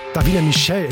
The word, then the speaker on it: Davina Michelle: Chris Martin und Coldplay We Pray für euch auf SR1 und das ist Davina Michael. Davina Michelle, Entschuldigung, so spricht man sie aus.